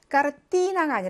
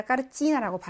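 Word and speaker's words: 까르찌나 (картина): 그다음에 그림이라는 거, 때 다음에 이가 왔네요. 그거에 해당하는 모음이 있죠. 이가. 그래서 까르띠나가 아니라 까르찌나라고 발음하셔야 됩니다.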